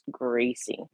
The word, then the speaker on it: Greycie: And the gray cat, her name is Gracie, and we spell it with G R E Y C I E. So it's Gracie, like her color because she's mm-hmm.